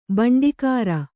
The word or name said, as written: ಬಂಡಿಕಾರ